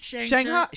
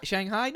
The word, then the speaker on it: Shang-Chi: I think it's Shang-Chi. – Shanghai? No, that's not Shanghai.